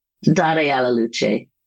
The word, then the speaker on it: Dare alla Luce: And I had asked you to place art from another series, Dare alla Luce, I think I'm pronouncing that correctly, for the cover of our Mythologies of Loss issue. Am I pronouncing that correctly? – Dare alla Luce.